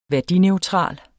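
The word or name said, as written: værdineutral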